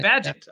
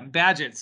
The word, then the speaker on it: Bagehot: Is it Bagehot, The Life and Times of the Greatest Victorian? Is that how you say that name? – Bagehot. – Okay. Yeah, Bagehot.